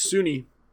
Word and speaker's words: SUNY: It was on a State University of New York, which is pronounced SUNY, but we decided to name our presentation Sunny SUNY, and we wore sunglasses for it.